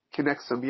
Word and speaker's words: Kinexum: So what's strikes me as, I guess, surprising is, as I visit your Kinexum site, did I pronounce it properly? – Kinexum, yes.